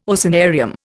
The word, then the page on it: oceanarium: Indian